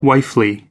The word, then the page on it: wifely: American English